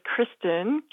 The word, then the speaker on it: Kristen: I'll say my name is Kristen, K-R-I-S-T-E-N, and the last name is spelled L-A-M as in Mary, O-R-E-A-U-X.